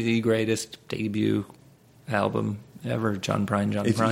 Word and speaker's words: John Prine: Like, if we went open for John Prine, three shows this John Bryan? – John Prine. – John Bryan, yeah. – Prine. – You're saying it wrong, but yeah.